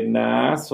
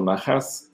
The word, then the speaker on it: Naas: Bueno, e-, esta es una buena pregunta, pero más bien aquí se podría entender que Naas o Nahas, según como lo pronuncies, Naas, eh, más bien es un tío de David.